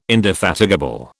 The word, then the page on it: indefatigable: British English